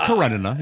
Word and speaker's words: Karenina: No, you said it right. It's Karenina. You're right.